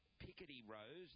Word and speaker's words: Picardy: We've got Picardy Rose, that's the correct pronunciation. It's a town in France, Picardy.